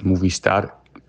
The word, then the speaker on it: Movistar: Die Spanier rollen ja immer gerne das R. Also im Gesamten wäre es sozusagen das El Equipo Movistar. Und jetzt Movistar, ja, das R wird am Ende einfach nur ein bisschen gerollt. Nichts Spektakuläres.